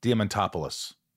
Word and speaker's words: Diamantopoulos: This is called Shit Talking with Chris Diamantopoulos.